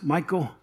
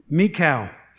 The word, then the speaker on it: Michal: I've heard it pronounced Michael, but I knew a Jewish girl, and her name was Michal. And so I'm going to pronounce it that way, all right? Michal.